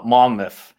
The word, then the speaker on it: Monmouth: So then you made the move to Monmouth.